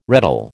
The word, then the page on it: reddle: American English